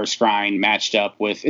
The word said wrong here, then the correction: Scrine: – it's Buster Screen. I don't know why I always say Scrine. It's spelled Scrine, but it's pronounced Screen.